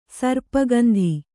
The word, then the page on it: ಸರ್ಪಗಂಧಿ: sarpa gandhi